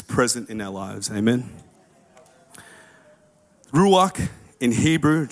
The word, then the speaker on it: Ruach: the next R uh, is Ruach, where my Hebrew people are. Jay Shelley. Am I saying that right? Ruach? Yeah, they got a thumbs up. Ruach,